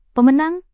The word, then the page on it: pemenang: Indonesian